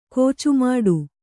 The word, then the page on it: ಕುಚ್ಚುಮಟ್ಟೆ: kuccumaṭṭe